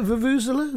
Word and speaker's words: vuvuzela: That was the sound of ITV's theme music to their coverage of World Cup 2010 South Africa, subtitled The Fucking Vuvuzelas. – Oh, blimey. – Is that how you pronounce it? Vuvuzela? – Vuvuzelas. – Vuvuzela. – Vuvuzelas.